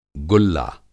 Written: गुल्ला

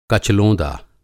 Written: कच-लोंदा

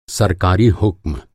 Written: हुक्म

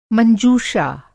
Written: मंज़ूषा

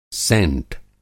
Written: सेंट

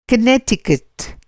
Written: कनेक्टिकट

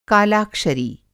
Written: कालाक्षरी

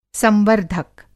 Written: संवर्द्धक

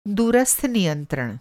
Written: दूरस्थ नियंत्रण

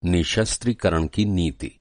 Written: नि:शस्त्रीकरण

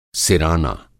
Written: सिराना

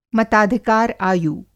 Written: मताधिकार आयु

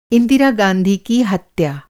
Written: इंदिरा

